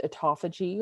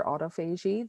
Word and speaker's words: autophagy: It's been studied for about 30 years, tons of health benefits with this, improved metabolism, increased autophagy or autophagy. There's lots of different ways to pronounce that word.